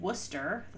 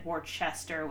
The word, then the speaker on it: Worcester: I can't remember if he said Worcester, which is incorrect. Mm-hmm. I mean, they're both incorrect, or Worcester, but those are the common ones.